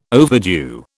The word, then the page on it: overdue: British English